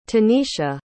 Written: tanicia

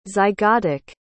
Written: zygotic

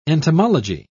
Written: entomology

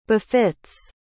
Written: befit's

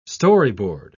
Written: storyboard

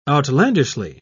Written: outlandishly

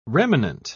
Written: remanent

